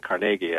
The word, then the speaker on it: Carnegie: And you had at that time, you had people who were corporate titans, many of them, Andrew Carnegie, most notably, Carnegie, I think is how he preferred to pronounce it, saying that having all of this wealth and being unaccountable to the public was a social wrong.